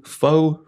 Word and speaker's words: pho: Speaking of new things, down here in Jackson, the new pho restaurant has opened.